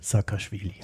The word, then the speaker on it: Saakashvili: Wer zur Hölle ist Michael Saakashvili. – Ja, die Aussprache war ganz korrekt.